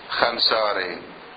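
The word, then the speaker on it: خوانساري: الخونساري طبعا هي بالفارسي ماذا تقرأ؟ خنساري. خنساري لماذا؟ قاعدة صرفية في الفارسية، الواو تلفظ لكن في موارد لا تلفظ الواو. ما هي هذه الموارد؟ يقولون الواو إذا جاء بعدها ألف وكان قبلها خاء تلفظها ألف، مثلا خواهر، خواهر يعني أخت، ما يلفظون خواهر يلفظون خاهر، كأن الواو معدومة.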